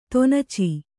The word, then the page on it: ತೊನಚಿ: Kannada